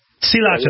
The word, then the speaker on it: Sriracha: Sriracha. That's how David Tran pronounces it, and I trust David Tran's judgment.